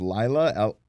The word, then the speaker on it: Laila: Hosted by Hulk Hogan and I believe it's pronounced Laila, L-A-I-L-A Ali, which is Muhammad Ali's daughter.